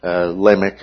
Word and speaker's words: Lamech: And it seems, and especially in the person of Lamech, Lamech is the way it's often pronounced.